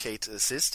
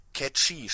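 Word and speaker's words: Cait Sith: Und, oder wie ich erfahren habe, der heißt ja nicht Kate Sis, äh, der heißt ja Catchy, spricht man das ja eigentlich aus, weil es auf einer.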